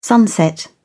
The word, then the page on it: sunset: British English